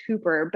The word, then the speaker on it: hyper: So there is a prefix that it's actually pronounced hooper, but it's spelled like hyper.